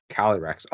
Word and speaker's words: Calyrex: Calyrex.